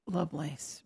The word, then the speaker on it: Lovelace: Ada Loveless. Lovelace? – Lovelace, right.